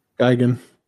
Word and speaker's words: Gigan: Gigan or Gigan? – Gigan. – I keep saying it wrong. I'm horrible. Gigan.